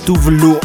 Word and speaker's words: Tove Lo: Dal 2014 Stay High di Tove Lo che in realtà eh, si dovrebbe pronunciare Tuvlu o qualcosa del genere, perdonateci, ma non conosciamo la lingua svedese e le loro e le sue pronunce.